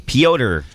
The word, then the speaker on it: Pyotr: It's Peter. – No, it's not. – Pytor. – Pyotr Kochetkov. – Pyotr. Okay, whatever.